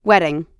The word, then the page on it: wedding: American English